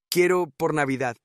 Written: quiero por navidad

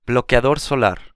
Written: bloqueador solar

